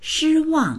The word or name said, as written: shi1wang4